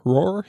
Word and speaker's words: Roar: Roar. Ik kan het niet zo lekker uitspreken, maar R-O-A-R.